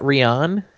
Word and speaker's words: Ryann: R-Y-A-N-N. Which apparently is a girl. – A-N-N? – Yeah. – Isn't that Rian? – It's Ryan, apparently.